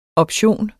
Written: option

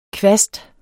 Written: kvast

